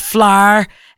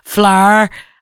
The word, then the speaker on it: flower: She's going to be there alongside Boys Life, Heather Small, Nadine Coyle, who apparently goes Flar. Have you seen Nadine Coyle bouncing around Shirdley Park Field? You've got to go up to her and go Flar. As in flower, that's apparently how she pronounces it.